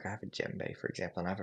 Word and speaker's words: djembe: Djembe, D-J-E-M-B-E.